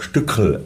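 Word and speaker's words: Stückl: Der Stückel, der Regisseur, also Oberammergau, der spricht sich eben auch Stückel aus.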